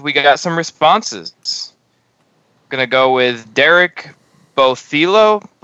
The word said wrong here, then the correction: Botello: Bothelo. – Botello. Sorry, Derek. I butchered your last name.